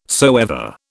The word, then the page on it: soever: British English